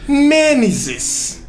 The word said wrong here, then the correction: Mímesis: Mênesis. – Mímesis. – Mímesis. Mímesis. Eu falei errado o programa inteiro, mas não dá nada não, tá ligado?